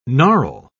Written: gnarl

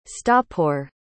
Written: stoppeur

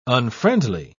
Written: unfriendly

